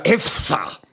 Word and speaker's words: EFSA: Euroopa toiduohutusamet on siukene amet, mille nimi hääldub lühendina EFSA, nagu Eesti filmi sihtasutuski.